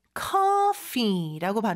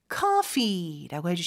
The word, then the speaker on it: coffee: 그래서 같은 키읔 같은데 사실 영어는 그 C 발음이 입 앞쪽에서 k 요런 소리라고 보시면 돼요. 그래서 커가 아니라 성대 쪽이 아니라 앞쪽에서 k 바람 내뿜는 소리처럼 해주시면 되고요. 거기에 coffee, F 발음도 역시 윗니 아래 입술을 댄 상태에서 바람을 내뿜는 소리죠. 그리고 길게 coffee라고 해주시면 됩니다.